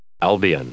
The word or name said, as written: albion